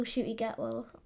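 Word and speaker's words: Ncuti Gatwa: So maybe you'll keep Jodie Whittaker's TARDIS till Shooty Gatwell. Is I'm saying that right? Yeah. – Yeah. Gatwell. I don't think so.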